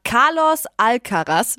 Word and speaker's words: Alcaraz: Also ich weiß ja nicht, was ihr mit 19 so gemacht habt, aber Carlos Alcaraz, spricht man so aus, oder? Yes. Ja.